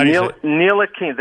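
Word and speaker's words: Ntilikina: Attila Keena? – Neil Akina. The T is silent.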